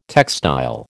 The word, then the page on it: textile: American English